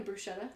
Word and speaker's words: bruschetta: You know bruschetta? – Yeah. – Well, it's the Italian way to say it is bruschetta. – Okay. – Pronounce the C.